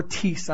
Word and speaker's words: tis: And that word is tis. Or tis, I actually believe is pronounced T-I-S, and it's the word some.